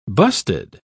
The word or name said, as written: busted